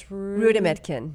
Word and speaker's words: Rudametkin: It's Rudametkin.